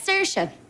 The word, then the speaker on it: Saoirse: It's Saoirse. It means freedom. But I've got a little problem. You see, it's spelled wrong. It's a full typo. S-A-O-I-R-S-E.